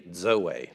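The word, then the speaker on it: Zoe (ζωή): This word is pronounced D-Z-O-A, Zoe. It's almost like there's a D in front of it.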